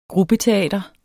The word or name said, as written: gruppeteater